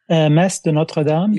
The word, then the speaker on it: Messe de Notre Dame: Messe de Notre Dame, am I pronouncing that correctly? – Messe de Notre Dame? – Yeah.